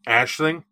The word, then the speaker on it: Aisling: Ailing, or is it Aisling? – Ashling. Ashling, if you go by the Irish pronunciation.